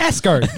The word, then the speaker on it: Asgard: And so yeah, that was their research and their role and ultimately what they're about to uncover before they actually see it is Asgard, or Asgard, if you want. – Asgard. Yeah, say it right.